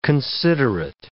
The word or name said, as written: considerate